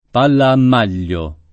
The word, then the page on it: palla a maglio: lingua italiana